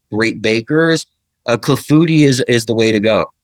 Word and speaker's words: clafoutis: And the clafoutis, is that how you pronounce it? – Clafoutis.